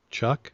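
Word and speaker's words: Chuuk: How do you pronounce that, guys? Chuck.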